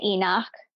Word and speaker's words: Enoch: I say Enoch.